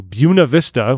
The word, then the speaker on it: Buena Vista: I'm assuming it's bigger than just my stake if they're bothering to go down to Buena Vista, which is how it's pronounced for some reason beyond understanding.